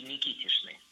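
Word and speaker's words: Никитичны: Строгая норма, конечно, требует, чтобы были Никитичи и Никитишны. Произносится через «ша», пишется через «ч», но в любом случае без суффикса «о».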